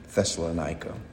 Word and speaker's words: Thessalonica: the Greeks would call it Thessaloniki, but we would, in English, typically pronounce it Thessalonica.